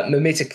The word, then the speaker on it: mimetic: And in terms of that mimetic theory, am I saying that correctly? – You are. Well done.